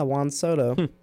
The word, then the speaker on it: Juan Soto: Is that how you say his name? – Yeah, Juan Soto.